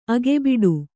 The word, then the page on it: ಅಗೆಬಿಡು: agebiḍu